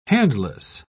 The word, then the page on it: handless: British English